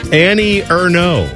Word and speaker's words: Annie Ernaux: Annie Ernaux. – Oh, Annie Ernaux. – Annie Ernaux. – Uh-huh. – How do you spell that, Bone? – E-R-N-A-U-X.